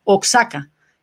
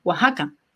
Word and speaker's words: Oaxaca: Porque cuando fui a México me acuerdo que yo decía Oaxaca. Y alguien me dijo, no, es, ay, ¿cómo era? Oaxaca. Yo decía, pero si dice Oaxaca, yo diciendo, definitivamente tengo que mejorar mi nivel cultural mexicano, seguro.